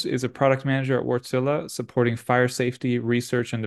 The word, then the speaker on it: Wärtsilä: And joining me today are Chris Groves and Michal Syed Navid from Wärtsilä, or Wärtsilä, as it's pronounced in its native language.